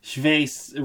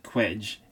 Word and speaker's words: Cwej: Chevet is spelled C-W-E-J. – I've read this as Quedge my entire life. – Yeah. Here's the thing. Chevet rhymes with straight. This is the trick. Everyone in universe reads it and pronounces it Quedge, and Chris has given up on correcting them.